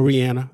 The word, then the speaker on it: Rihanna: Her name is Rihanna, or Rihanna. That's how you're supposed to pronounce it. And they named song RZA. A-T-H-E, Alston?